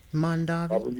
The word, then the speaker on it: Mondavi: Mondavi.